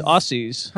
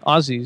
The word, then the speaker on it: Aussies: It's Aussies. No, they call them Aussies. Aussies. – How do you say it? – Aussies. – Aussies. – Australians. – It's no Z. A-U-S-S-I-E-S.